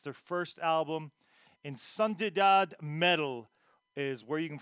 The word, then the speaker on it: Insanidade: Insan- Insanidade. Insanidade. That is what the the name uh, better pronunciation into Portuguese is.